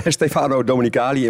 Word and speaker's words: Stefano Domenicali: Had vorige week een afspraak met Stefano Domenicali in Bangkok.